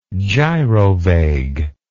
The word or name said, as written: gyrovague